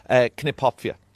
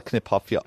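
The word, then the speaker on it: Kniphofia: Now, knip, knai, whatever you want to call it, I call it Knipphopfia. I've always called it that. I know the P is supposed to be silent, but look, that's how I pronounce it, and I'm sticking with it.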